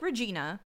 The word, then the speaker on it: Regina: Her name is spelt like Regina. But it is pronounced Regina. – Like vagina. – Yes, that is in my notes. Rhymes with vagina.